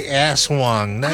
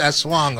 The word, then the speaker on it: aswang: But yeah, because Americans say aswang. It's not aswang. It's aswang. Okay.